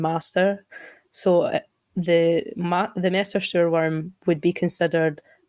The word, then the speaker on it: wyrm: It's worm as in worm with a Y, so W-Y-R-M, yeah.